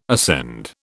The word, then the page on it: ascend: British English